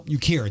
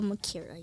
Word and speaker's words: Mukiira: Gabrielle Mukiira, did I say that right? – Yeah. – You probably get your last name mispronounced. Gabrielle Mukiira, yeah.